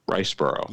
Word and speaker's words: Riseborough: It's actually Andrea, I think it's pronounced Riceboro.